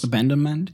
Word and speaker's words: Abandonment: Abandonment. Spricht man das so aus? – Abandonment, ja.